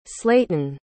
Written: slaton

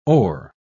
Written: hors

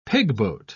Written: pigboat

